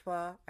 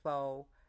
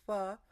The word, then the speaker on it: pho: It's pronounced pho. I know a lot of Americans say pho. It's pho, a pho thai or a pho gà, chicken or beef.